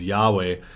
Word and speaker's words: Yahweh: So if you were reading a scroll in the Old Testament and you came across that word, which is Yahweh or Yahvah, depending on how you want to pronounce it,